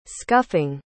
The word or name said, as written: scuffing